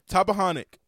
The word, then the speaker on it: Tappahannock: Tappahannock. I still don't know how to say this. – Tappahannock, Virginia.